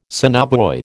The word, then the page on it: sonobuoy: American English